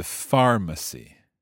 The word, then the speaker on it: pharmacy: Y tiene el acento en far. The pharmacy.